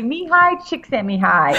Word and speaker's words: Mihaly Csikszentmihalyi: I read it, Mihaly Csikszentmihalyi, but apparently the L is silent. So I had to drive all the way back down to LA just to say his name in the microphone properly.